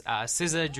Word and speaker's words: SZA: So if you're not familiar, it's spelled SZA, all caps. SZA, Drew Barrymore.